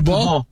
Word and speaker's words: Kubo: Bueno, fíjate que me dijeron que se pronuncia Kubo.